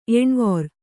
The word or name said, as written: ಎಣ್ವೊರ್